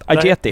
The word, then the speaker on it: Ajeti: Är det, uttala ditt efternamn rätt? – Ajeti? Helt riktigt, Ajeti.